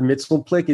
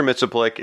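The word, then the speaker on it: Mxyzptlk: And the reason that Shag said Mr. Mixoplate is because that's how they pronounce it on the Super Friends.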